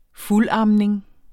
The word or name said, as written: fuldamning